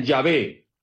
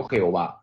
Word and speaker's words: Yahvé: Cuando a las cuatro letras sagradas que se pronuncian Yahvé, se les puso las vocales de Adonai, salió Jehová.